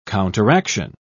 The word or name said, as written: counteraction